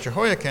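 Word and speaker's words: Jehoiachin: Basically, he was Jehoiakim, and I believe the CH is pronounced K, but it's not a big deal. Either way, it tends to be in that way in most places where you see CH.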